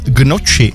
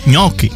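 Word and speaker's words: gnocchi: A taky Marťa, ahoj, da, tak mě tahá za uši, když někdo řekne, že by si dal gnoči. Správně je ňoky.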